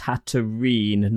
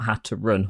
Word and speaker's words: Hatterene: But Lachlan's saying it's Hatterene, not Hatterun.